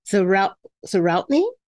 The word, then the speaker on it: Zawrotny: Zawrotny? – Well done.